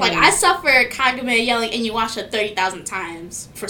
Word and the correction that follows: Kagome: Anyone pronounce it Kagame. That's interesting. Sorry. Continue. – I mean, that's how it would be written. Like, Kagome. – That's how it... Yeah. – Yeah. – How... Wait. How yeah, you heard it pronounced? – Kagome. – Oh, Kagome. Yeah.